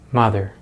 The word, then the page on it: mother: American English